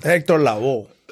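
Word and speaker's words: Héctor Lavoe: Hector, is it Hector Lovo? Did I say that right? Hector, he's a salsa. He's a salsa. – Hector Lavo. – yeah. – I was like, Hector Lugo. – That's gringo. – Like Hector Lavo.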